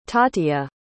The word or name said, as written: tatia